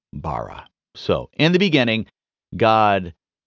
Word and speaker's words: bara: Now, the Hebrew word translated as created is the word bara. Now, I pretty much poorly pronounced that word because I can't roll my R's, and that word is supposed to have a rolling R-type sound,